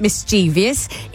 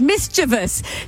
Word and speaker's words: mischievous: It doesn't rhyme with previous. It isn't mischievous. It rhymes with grievous. Mischievous.